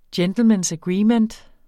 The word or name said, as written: gentleman